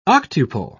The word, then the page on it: octuple: British English